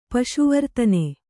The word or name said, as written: ಪಶುವರ್ತನೆ